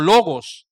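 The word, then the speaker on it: logos (λόγος): Por eso es que no se traduce, no se pronuncia como palabra, se pronuncia como logos.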